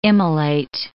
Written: immolate

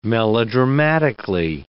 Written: melodramatically

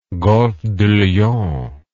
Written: golfe du lion

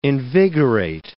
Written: invigorate

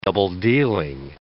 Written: double-dealing

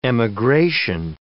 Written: emigration

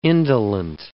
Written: indolent

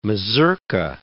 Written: mazurka

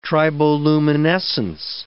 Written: triboluminescence

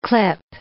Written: clip

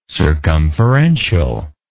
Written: circumferentially

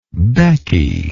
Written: becky

